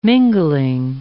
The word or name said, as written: mingling